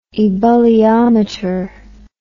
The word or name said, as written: ebulliometer